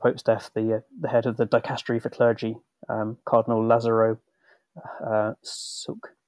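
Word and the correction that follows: Lazaro You Heung-sik: Sorry, Dame, I'm going to have to just double check the pronunciation of his name because forgive me here. Cardinal Lazaro Yu Hung Sik, or Cardinal Yu.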